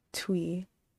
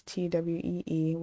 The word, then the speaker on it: Thuy: And you pronounce her name like Twee. I searched her up on Instagram, and she wrote it, she described her name like in parentheses Tweee when it's spelled Thuw. Oh my god, Thuy, but it's pronounced Twee.